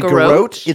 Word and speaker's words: garrote: I don't know how to pronounce it. Garrote? It's a garrote.